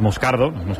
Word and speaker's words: Moscardo: Como bien ha pronunciado Unai es Moscardo, brasileño, sí.